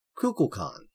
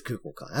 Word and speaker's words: Kukulkan: So yeah, we're gonna be talking about ye old Kukulkan. I think that's how you pronounce it. Some other people pronounce it other ways, but it is Kukulkan. That's how he says it in game.